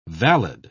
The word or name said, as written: valid